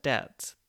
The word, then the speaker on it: debt: Whereby with the word debt, this is an odd case of the B never actually having been pronounced. It was added in spelling by scholars of the Middle Ages, who noted the connection to the Latin word debitum. There was no B before, but because of this connection, scholars added a silent B to the word, even though it never had any business being there.